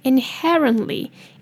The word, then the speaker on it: inherently: overall else is inherently, inherently 的 IN 念 in 的音应该没有问题后面的 E 念 ed 的音那 REN 就是 run 那再来后面那个 T 如果念得快的话可以省略如果不想要念得这么含糊的话也可以把 T 发音出来没有问题那后面再加上 LY inherently inherently